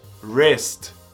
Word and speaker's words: wrist: Pulso, wrist. Esquece o W na pronúncia. Wrist.